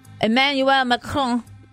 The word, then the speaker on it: Emmanuel Macron: Emmanuel Macron.